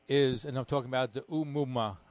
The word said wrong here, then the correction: Oumuamua: I hope I pronounced it correctly. – Oumuamua, yes. – That's in Hawaiian.